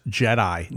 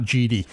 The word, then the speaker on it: Jedi: So I actually went back and changed the pronunciation so it properly says Jedi, not GD.